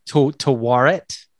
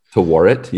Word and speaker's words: Tawaret: It's like Timurat. – Tawaret. – Tawaret. Yeah, I looked it up.